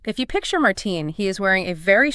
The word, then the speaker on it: Martijn: And it is spelled Martijn, pronounced Martine.